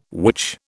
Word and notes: which: British English

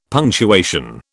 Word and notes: punctuation: British English

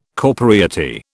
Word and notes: corporeity: British English

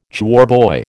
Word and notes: choirboy: American English

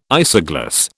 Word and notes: isogloss: British English

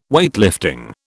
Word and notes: Weightlifting: British English

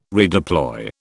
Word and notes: redeploy: British English

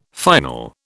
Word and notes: final: British English

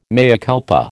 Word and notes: mea culpa: American English

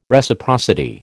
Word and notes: Reciprocity: American English

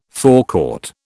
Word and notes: forecourt: British English

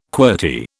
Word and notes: qwerty: British English

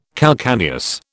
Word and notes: calcaneus: British English

